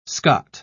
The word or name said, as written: scot